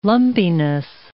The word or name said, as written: lumpiness